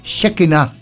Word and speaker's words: Shekinah: Ang pronunciation daw sa Hebrew ng pangalan ni Shekinah Abalon ay Shekinah.